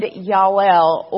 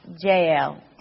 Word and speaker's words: Jael: If you remember last night, I did a character sketch of two women in Scripture, Deborah and Yael, or most of the time you'll hear her name pronounced Jael.